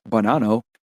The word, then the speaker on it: Bonanno: The Bonanno crime family, pronounced Bonanno,